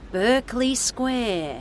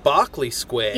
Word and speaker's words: Berkeley: Here it is, Berkeley Square. – I think it's pronounced Barclay Square.